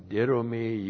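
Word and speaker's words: didomi (δίδωμι): Now, didomi, you all have seen this many, many times. D-I-D, long O-M-I, didomi. And a couple of things.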